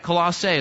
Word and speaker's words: Colossae: Colossae is how you say that.